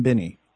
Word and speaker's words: Binney: Did I say his name right? Does he pronounce it Binney? – Good morning. And yes, you pronounced it correctly. It is William Binney.